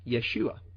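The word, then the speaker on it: Yeshua (ישוע): pronounced Yeshua.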